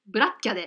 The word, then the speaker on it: black cat: あのアメリカ英語は最後の t をあんまり発音しないので、キャで大丈夫です。 – ブラッキャでカタカナだったら、 black cat ですね。